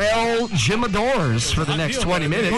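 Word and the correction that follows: Jimador: Will you, someone please let Joe know it's pronounced Himador?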